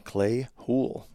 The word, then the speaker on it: Clay Houle: All right, now we're headed up to the Turtle Mountains of North Dakota with Clay Houle.